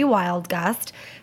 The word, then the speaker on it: Wildgust: My name is Shelby Wildgust. Fun fact, my last name is a compound word. It is spelled exactly the way it sounds.